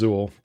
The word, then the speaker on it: XUL: Yeah, so XUL is pronounced Zool typically.